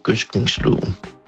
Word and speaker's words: gødskningsloven: Jeg tror nok, at jeg hælder til den der uden blødt D, altså hvor det hedder gødskningsloven.